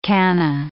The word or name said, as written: canna